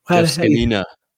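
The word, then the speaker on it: Zgonina: Ryan Kerrick and coach, what is his name? Zagina? Oh, Lord. – Scanina. – Scanina, there it is. Yeah. I put the wrong emphasis on the wrong spot.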